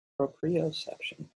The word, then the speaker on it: proprioception: Say the word again. – Proprioception. Right word? – Okay, it was the right word. For some reason, I dropped a syllable in my head. I don't even know which syllable. It just didn't seem right.